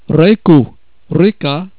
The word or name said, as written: reka